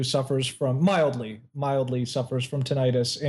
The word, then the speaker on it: tinnitus: Martin suffers from tinnitus. Is that how you pronounce it? – Tinnitus. – Yeah. Tinnitus. Thank you.